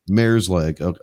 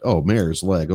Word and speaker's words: mare's leg: Mare's leg. – Mare's leg.